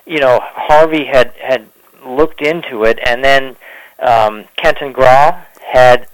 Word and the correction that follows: Grua: Grua, G-R-U-A, yeah. – Grua, okay. I've never, you know, been aware of how that should be pronounced.